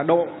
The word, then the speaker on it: độ: họ không biết được cái từ đó thì Phật giáo chúng ta đọc là độ, cho nên họ toàn dịch là gì? Cõi tịnh thổ, cõi đông thổ, cõi tây thổ, cõi tịnh thổ, vân vân. Không sai, vì trong chữ Hán nó là chữ thổ. Nhưng mà Phật giáo chúng ta đọc chạy đi, phát âm chạy đi là độ.